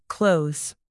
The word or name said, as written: clothes